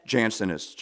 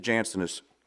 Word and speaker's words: Jansenist: He was a Janus. Uh, how do I say it, sister? Janus? Jansenist. Jansen. He was a Jansenist.